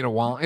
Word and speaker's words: Wang: To even have it be like when the principal walks in and says, Jin Wang, and you have to correct the pronunciation of, Wong. It's like, oh my God, like, for so many people, that's the first time they're ever finding out watching this show that it's not Wang.